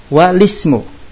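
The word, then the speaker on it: walismu (والاسم): Nah ini yang dikatakan hamzah wasal di tengah kata atau pertengahan antar kata dia hilang, nggak kebaca. Alismu dan walismu. Yang bacanya alismu atau walismu salah.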